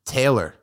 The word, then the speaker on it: Taylor: I say it Taylor, but I didn't grow up talking like that. So it's some kind of broadcast fear of microphone gods thing. Taylor, that's how you say my last name. You can pronounce that however, pronounce at however you want.